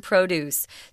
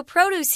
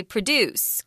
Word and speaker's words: produce: So produce here is a noun, and it's not a verb, so you can't say produce, guys. This is very tricky. It's one of these words that's spelled the same but is pronounced completely differently.